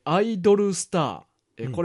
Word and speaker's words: アイドルスター: ニヤニヤしながら納得しつつ、自分はガスが漏れてませんかと両手と股間を広げてと、しょんべん垂れ流しお嬢様のところが好きだったなと思い返していました、うん、アイドルスター、これはハート、うん、ドル星って書くねんけど、うんうんうん、これをアイドルスターって読むねんけど、